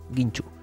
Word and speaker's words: Guinxu: Y el otro Ginchu es G-U-I-N-X-U. Ginchu.